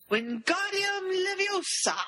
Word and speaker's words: Wingardium Leviosa: Wingardium Leviosa. – Well, like Leviosa. – Excellent pronunciation, darling.